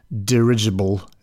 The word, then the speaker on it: dirigible: I think I don't understand the difference between airship, blimp, Zeppelin, dirigible. Is that how you pronounce it? Dirigible? – Sure, that works.